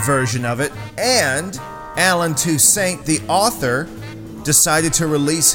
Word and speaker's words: Toussaint: The song is Get Out of My Life, Woman, and it was written by New Orleans great Alan Toussaint. I used to call him Toussaint, but then I heard an interview where he said the correct pronunciation of his name is Toussaint, so I've changed my ways, baby.